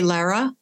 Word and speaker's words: Lara: So do you say Lara? – Yes.